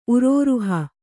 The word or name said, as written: ಉರೋರುಹ